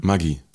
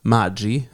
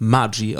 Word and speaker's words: Maggi: Maggi. – Genau. Falsch. Es heißt Maggi. Es ist italienisch und es wird Maggi ausgesprochen. To be for real, kein Scheiß.